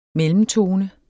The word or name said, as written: mellemtone